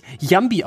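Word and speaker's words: Llambi: Und den habe ich neulich mal gegoogelt und habe rausgefunden, dass er gar nicht Lambi heißt, sondern er eigentlich Jambi ausgesprochen werden müsste, weil er Spanier ist.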